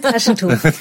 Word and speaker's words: Taschentuch: Brauchen Sie ein Taschentuch? – Ein Taschentuch. Bah oui, c'est trop triste. – Non, je l'ai pas très bien prononcé. – Si, c'est très, c'est, en fait.